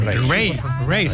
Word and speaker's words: Raye: Ray.